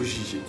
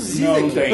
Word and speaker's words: Žižek: Acho que a pronúncia é algo como tipo Žižek.